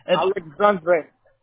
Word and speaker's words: Alexandre: So it's Alexandro, or just spell it for me. – Great. Alexandre. – Dre. Okay. – It's a D-R-E in the end. D-R-E. – Oh, D-R-E.